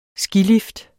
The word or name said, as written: skilift